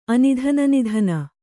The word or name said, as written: ಅನಿಧನನಿಧನ